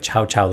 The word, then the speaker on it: Ciao: That song is called Ciao Ciao, and it is by La Rappresentante de Lista. – I like how you say it, like chow chow, like the dog food.